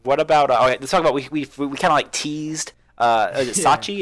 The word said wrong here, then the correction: Sachio: Yeah. Is that how you say it? – Sachio. – Sachio.